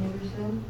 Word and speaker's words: Henderson: My name is Kathleen Henderson, H-E-N-D-E-R-S-O-N.